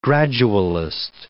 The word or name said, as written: gradualist